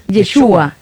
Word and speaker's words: Yeshua: En su mente, eh, eh, antes de pronunciarlo, póngale la tilde en la U. – Yeshua.